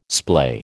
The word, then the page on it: splay: American English